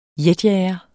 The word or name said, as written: jetjager